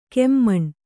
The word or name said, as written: ಖಮಣಿ